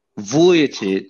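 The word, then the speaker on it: Vučević: Did I say that right? Vukicic? Vujicic. All right, close enough.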